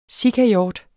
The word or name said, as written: sikahjort